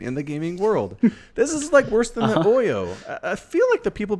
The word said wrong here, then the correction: Ouya: Just to be fair, you know it's pronounced Ouya, right? – Nope, sure don't. – Ouya.